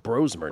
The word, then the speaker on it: Brosmer: Perhaps Josh Johnson for Washington, Max Brosmer, we're learning it's pronounced Brosmer now, Max Brosmer for the Vikings, and Chris Oladokun of the Kansas City Chiefs.